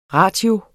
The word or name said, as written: ratio